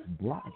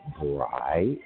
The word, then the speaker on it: Brian: It's Brian, and that's why my name is so easy to spell itself. Brian, right? – But people butcher it all the time. Hey, Brain.